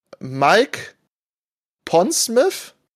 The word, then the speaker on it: Mike Pondsmith: Äh, Mike Pondsmith.